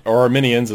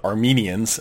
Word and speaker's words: Arminian: They would never have understood why people would say, we're not Calvinists or Arminians, as that one particular guy did not pronounce Arminian correct. He said Armenians, as if you were from Armenia.